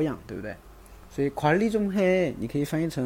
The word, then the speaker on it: 관리: 관 리 这个单词需要注意，它有音变啊。本来是宽宽 리 但是这个地方有一个同化现象，要读成“ 管理” 啊，“ 管理” 管理的意思。